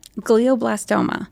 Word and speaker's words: glioblastoma: Glioblastoma.